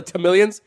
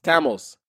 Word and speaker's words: Tamil: Ain't nobody calling each other Tamilians. We're not a goddamn reptile over here. It's Tamils. And if you really gave a shit about us, Tamil. That's how you say it.